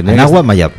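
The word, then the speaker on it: Anáhuac Mayab: Anahuac Mayab. – Yo creo que lo he dicho perfectamente. – En Mérida.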